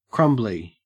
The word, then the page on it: crumbly: Australian English